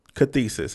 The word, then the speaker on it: cathexis: The first word is cathesis. – Carthesis or Cathesis is spelled C A T H E X I S.